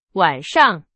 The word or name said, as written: 晚上